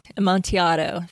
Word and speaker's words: Amontillado: Is that? I don't think Amontillado. I'm pronouncing that right. Amontillado. Thank you. Goodness, I'm terrible today. It's been a long day. Thank you for the correction.